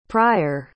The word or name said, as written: pryor